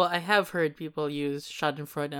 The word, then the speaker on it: Schadenfreude: And I did a little bit of research on this, and I found the correct pronunciation. It's this one. – Schadenfreude.